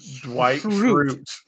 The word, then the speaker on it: Dwight Schrute: Dwight Schrute? – Dwight Schrute.